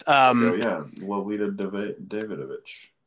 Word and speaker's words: Lolita Davidovich: So The lady that pulls him aside, Lolita Davidovich, I'm not sure how to pronounce her name. I believe the C is silent. – Lolita Davidovich.